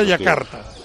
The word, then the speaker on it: Jakarta: En indonesio se escribe Jakarta, pero se pronuncia Yakarta.